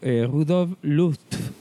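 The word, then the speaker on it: Rudolf Lutz: dirigida por Rudolf Luft. No sé si se pronuncia así. – Sí. – Sí, muy bien.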